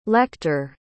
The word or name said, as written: lecter